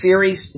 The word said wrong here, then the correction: Cery: His name is spelled C-E-R-Y. It's actually Kerry. He's in England, and I didn't know that they pronounce C-E with as a K.